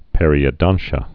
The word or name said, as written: periodontia's